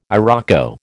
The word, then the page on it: iroko: American English